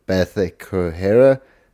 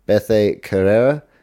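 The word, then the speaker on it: Bethe Correia: And for Carol Rosa, her last win was a decision win over Bethe Correa. Sorry, I butchered her name. Bethe Correa.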